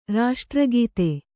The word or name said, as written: ರಾಷ್ಟ್ರಗೀತೆ